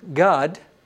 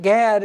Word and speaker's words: Gad: No, it's just that that A is pronounced like the A in father. It's an A sound, not an A sound. Just like it was in Dan, it's not Dan, it's Don. In G-A-D, the Hebrew name that you would call Gad if you'd pronounce it in English, is pronounced Gad in Hebrew. He says in verse 19, God, a troop shall ramp upon him, but he shall triumph at last.